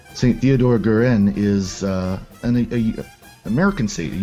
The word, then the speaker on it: Guerin: And then St. Theodora Guerin, how do you pronounce that? Guerin. That's on next Friday, and then next Saturday, I believe, is St. Francis of Assisi.